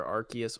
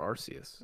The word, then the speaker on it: Arceus: Yeah, it can be either Arceus or Arceus.